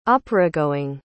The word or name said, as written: operagoing